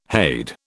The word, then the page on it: hade: British English